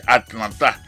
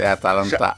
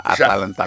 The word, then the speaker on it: Atalanta: Oui, pour me dire des noms Atalanta, je comprends que c'est mal prononcé. Atlanta. Il y a une grande ville qui existe dans l'État Georgie, dans le Parti Sud, pays. Une belle ville. – C'est Atalanta, Atalanta Bergamo.